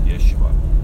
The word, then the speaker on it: Yeshua: Oh, So that is the song Yeshua, which of course comes from the Hebrew pronunciation of Jesus' name. Oh, it may have been pronounced, for example, when Jesus' mom would have called him, or people would have called him by his name, Jesus, Yeshua. So that song, it just really, really affected me.